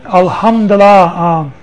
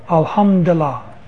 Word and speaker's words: Alhamdulillah: That's a little bit harder to Alhamdulillah, A-L-H-U-M-D-I, and then Allah. Alhamdulillah. That's how it's pronounced.